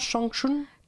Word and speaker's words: Changchun: C'est comme ça qu'on prononce Changchun Changchun.